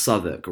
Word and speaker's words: Southwark: It's called, it's pronounced Southwark, right? But it's spelled South and then W-A-R-K. So me in my Spanish mode, I'm reading it literally.